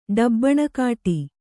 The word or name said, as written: ಡಬ್ಬಣಕಾಟಿ